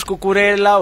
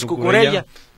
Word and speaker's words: Cucurella: Entonces, no pasa nada si le decimos Cucurella o si le decimos Cucurella. No pasa absolutamente nada, porque se escribe con doble L, y si pronunciamos la Y, pues va a estar bien pronunciado, y si nada más decimos Cucurella.